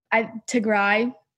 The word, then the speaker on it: Tigray: Yeah, it would be like Tigray.